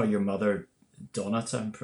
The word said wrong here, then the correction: Donita: I'm pronouncing her name correctly. – Donita, pardon me.